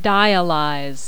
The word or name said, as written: dialyze